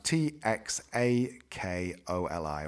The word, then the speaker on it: txakoli: but it's pronounced T X A K O L I, or sometimes can be just X A K O L I, and it's Txakoli.